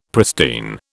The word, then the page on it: pristine: British English